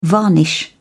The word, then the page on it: varnish: British English